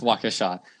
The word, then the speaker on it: Waukesha: The only time that a non-California team has broken 53K was in 2008 when Waukesha West of Wisconsin broke. How'd you pronounce that? – I had a mishap in my mouth. I know it's Waukesha. I think I said Waukesha.